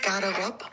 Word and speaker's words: Garderobe: Ähm, und es wird auch im Franzose französischen Garderobe ausgesprochen. Wenn, dann würde das E am Ende halt hinten wegfallen.